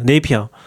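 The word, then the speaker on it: Napier: 주판이 있고 그 이름을 어떻게 발음하는지 모르겠는데 네비어스 본 네, 네비언가요? 네이비어? 뭐야? – 아 네이비어 그 네이피어. 네이피어? 그 로그 만든 사람 있잖아요.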